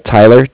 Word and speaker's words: Tyler: Sorry, my name is Tyler, T-Y-L-E-R, mm-hmm. And I'm calling with Cardiff. It's spelled C-A-R-D-I-F-F, as in Frank Frank.